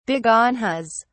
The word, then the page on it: begonias: British English